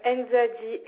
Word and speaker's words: Nzadi: Bien sûr, R-H-A-I-R Concept. Vous êtes madame Nzadi Farah. – Alors, plus lentement, s'il vous plaît, pour le nom de famille, que je n'écorche pas votre nom. – Nzadi, N-Z-A-D-I.